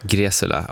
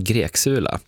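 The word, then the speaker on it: Greczula: Ja, du uttalade det helt rätt. Det var ju väldigt roligt under Melodifestivalen när han var med där, så var det en reporter på Ekot som envisades med att uttala hans namn Greksula.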